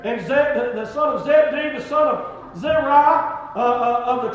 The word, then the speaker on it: Zabdi: And that is spelled Zabdi. Amen. I looked that up as well. And it's Z-A-B, amen, D-E-E. – Amen. – Zabdi.